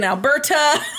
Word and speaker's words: Alberta: It started in Alberta. I don't know why I just said Alberta that way. – Wow. All the emphasis on the T. – I was super weird. – Started in Alberta.